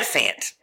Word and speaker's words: Fant: Did I go Font and Fant? It's Font, right? – I call him Fant, but I believe it's Fant.